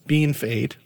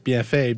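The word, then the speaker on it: Bienfait: We have colleagues, you know, who work on the French side here who might come in from out of province and they're shocked that it's not pronounced BFA because that's what it looks like.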